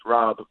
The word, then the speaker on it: Rob: So it starts with worldwide, and my first name is Rob, but my fifth-grade girlfriend, she couldn't pronounce the letter R. It sounded Wobbert. – Like Wob, and I was forever known as Wob.